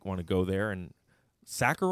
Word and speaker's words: Sachar: Sacker rhymes with cracker.